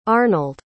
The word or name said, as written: Arnold